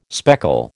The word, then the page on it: speckle: American English